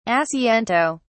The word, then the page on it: asiento: British English